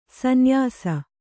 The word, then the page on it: ಸೂನ್ಯಾಸ: sūnyāsa